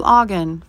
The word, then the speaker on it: Ogden: So I Americanized my name to Ogden for North Americans. It rhymes with noggin and login.